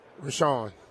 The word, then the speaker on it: Richaun: I hope I'm saying this right. R-I-C-H-A-U-N. How would you say that? – R-I? – R-I-C-H-A-U-N. – Rashawn. – That's what I've been saying.